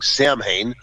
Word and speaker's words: Samhain: So Samhain is pronounced or spelled Samhain, so it looks like Samhain, but it's actually Samhain.